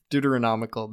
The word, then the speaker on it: Deuteronomical: I don't know if I'm saying that right. – Deuteronomical. – Deuteronomical. That's good.